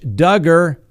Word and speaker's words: Duggar: Next on the docket, Josh Duggar, rhymes with hugger.